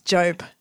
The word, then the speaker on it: Job: Is it Job or Yob? – It's Job.